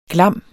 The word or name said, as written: glam